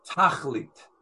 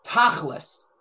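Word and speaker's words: tachlit (תכלית): Shabbos represents, and here's a Hebrew word called tachlit. In when I was growing up, and it was a more of an Ashkenazi pronunciation, and certainly with a Yiddish speaker, the tachlis.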